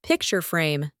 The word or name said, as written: picture frame